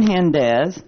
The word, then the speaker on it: Gendes: Susan Hendez, that's spelled G-E-N-D-E-S, and it's 313 South Atlantic, Daytona Beach, Florida.